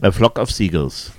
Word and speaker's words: A Flock of Seagulls: Flock of Seagulls, Seagulls, oder wie spricht man es aus? – A Flock of Seagulls.